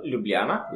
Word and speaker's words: Ljubljana: Our capital, Ljubljana, which is how you pronounce it, has 250,000 inhabitants.